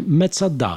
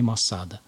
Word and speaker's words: Масада: Поэтому правильно Мецада все-таки, да, но ну, устоялось вот это произношение Масада.